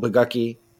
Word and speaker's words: Bogucki: The man, the myth, and the legend, Larry Horgle Bagucky. It rhymes with lucky. That's how I remember it, and don't mispronounce it.